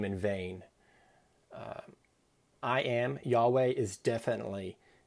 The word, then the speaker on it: Yahweh: We pronounce it kind of Yahweh, but it was a word that the Jewish folks, they did not use.